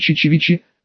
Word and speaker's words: Чечевичи: На западном берегу остановились на обед в рыбацком селе Чечевичи, с ударением на второе «ч», даже не представляю себе, откуда такое название.